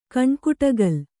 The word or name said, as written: ಕಣ್ಕುಟಗಲ್